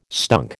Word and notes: stunk: American English

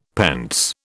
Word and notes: pence: British English

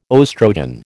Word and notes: oestrogen: American English

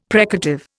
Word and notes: precative: Indian

